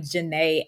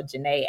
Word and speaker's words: Ja'Net: And on there, I am Janae Adams Speak. And my name is spelled like Janet, like Janet Jackson. So Janae Adams Speak.